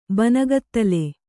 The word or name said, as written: ಬನಗತ್ತಲೆ